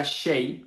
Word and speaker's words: Hsieh: Así que eh, tenemos la historia pues muy presente de Tony Shay eh, el apellido se escribe un poco raro, H-S-I-E-H, e y se pronuncia Shay Tony Shay eh, y el libro se llama Delivering Happiness.